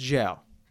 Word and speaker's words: Zhao: But Chloe, I think it's pronounced Zhao. Chloe Zhao, like we were just talking about before...